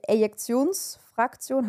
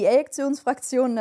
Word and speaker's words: Ejektionsfraktion: Die Ejektionsfraktion, habe ich das richtig ausgesprochen? – Ja. – Okay. Die Ejektionsfraktion, das ist der Anteil vom enddiastolischen Volumen, der ausgeworfen wird.